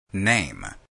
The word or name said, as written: name